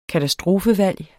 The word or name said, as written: katastrofevalg